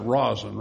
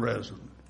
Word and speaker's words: resin: Now, most of us pronounce that rosin, right? Do you call it pine rosin or pine resin? Pine what? – Rosin. – Rosin. That's what I call it, too. But you hear most people say pine resin. You know, it's spelled R-E-S-I-N, resin.